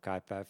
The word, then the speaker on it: Kuiper: So Kuiper takes its name and the pronunciation of Kuiper from the astrological body, the Kuiper Belt.